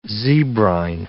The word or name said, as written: zebrine